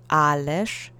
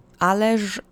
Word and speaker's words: ależ: Ależ is spelled with the z at the end, but again, with speech, you sometimes soften syllables. So let's say ależ together three times.